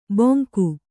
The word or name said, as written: ಬೊಂಕು